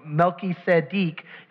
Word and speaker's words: Melchizedek: Because the scripture says that Jesus comes in the order of Melchizedek, or Melchizedek, if you want to say it right and properly and impress your friends.